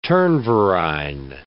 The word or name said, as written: turnverein